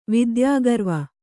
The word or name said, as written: ವಿದ್ಯಾಗರ್ವ